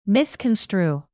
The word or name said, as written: misconstrue